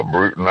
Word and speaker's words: Brewton: What were some of your early influences, not just in music, but in life back in Brewton, I believe is the proper pronouncing? – Yeah, Brewton, Alabama.